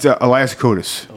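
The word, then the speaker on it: Elias Koteas: It's Elias Kotas.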